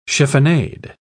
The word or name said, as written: chiffonade